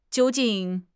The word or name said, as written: jiu1 jing4